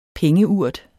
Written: pengeurt

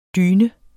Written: DYNER

